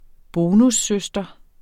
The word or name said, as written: bonussøster